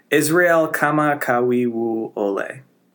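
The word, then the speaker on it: Israel kama kawi wu ole (Israel Kamakawiwoʻole): Israel kama kawi wu ole.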